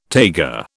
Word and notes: taiga: British English

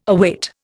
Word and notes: await: Indian

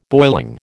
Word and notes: boiling: American English

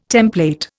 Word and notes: template: Indian

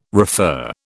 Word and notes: refer: British English

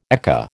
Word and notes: ekka: American English

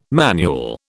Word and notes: manual: British English

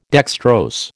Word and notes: dextrose: American English

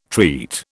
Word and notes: treat: British English

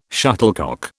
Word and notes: shuttlecock: British English

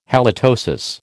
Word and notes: halitosis: American English

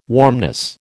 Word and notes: warmness: American English

